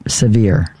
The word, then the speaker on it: Sevier: It's pronounced severe. Sevier County.